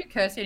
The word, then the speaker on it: Kirsty: You can't do Kirsty anymore. It rhymes with thirsty.